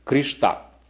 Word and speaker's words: Кришна: В Индии Кришна часто произносится как Кришта. Кришта, вот так в некоторых штатах называют.